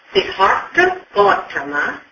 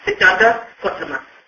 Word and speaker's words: Siddhartha Gautama: So there's a couple of pronunciations that you can pronounce his name, whether in Siddhartha Gautama or Siddhartha Gautama.